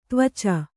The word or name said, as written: ತ್ವಚ